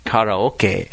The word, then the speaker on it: karaoke: You always need a little bit of alcohol when you're going to be karaoke, or we should pronounce it properly for all our Japanese listeners, karaoke.